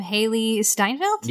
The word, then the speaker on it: Hailee Steinfeld: I don't know a lot about Hailee Steinfeld.